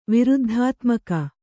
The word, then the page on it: ವಿರುದ್ಧಾತ್ಮಕ: viruddhātmaka